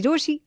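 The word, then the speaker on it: Rochie: रोशी नाम की स्पेलिंग है RO CHIE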